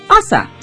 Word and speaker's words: AAHSA: He's president and CEO of the American Association of Homes and Services for the Aging, and it's AAHSA, or they pronounce it ASA.